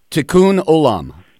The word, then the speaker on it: tikkun olam: Is it tikkun olam? – Tikkun olam.